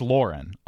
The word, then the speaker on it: Lauren: I am always, or maybe I can just never quite remember whether it's supposed to be Ralph Lauren or Ralph Lauren. But it is Lauren on the first syllable.